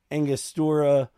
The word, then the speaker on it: Angostura: Um, I I did three Angostura.